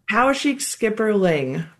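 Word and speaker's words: Poweshiek skipperling: The Powashik Skipper Butterfly. Did I say that right? – Powashik Skipperling. But it is a skipper.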